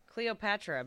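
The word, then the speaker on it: Kleopatra: There is a metallic asteroid shaped like a dog bone named Calipatira. Calipatira. Okay. – There. Oh, Cleopatra, but it's spelled with a K. I don't know. I've never seen it spelled like that.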